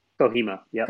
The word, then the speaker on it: Kohima: How do you pronounce it? Kohima? – Kohima, yeah.